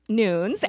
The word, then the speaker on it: Nunes: Allison Nunes, pronounced Nunes, as you would get from her profile, is the Director of Global Events and Event Marketing for LinkedIn.